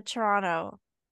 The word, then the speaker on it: Toronto: The first T is a little different because you're not saying it Toronto. You're saying like Toronto. Like it's softer.